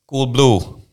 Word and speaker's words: Coolblue: Hoe spreek je dat in het Duits uit? – Coolblue. – Gewoon hetzelfde.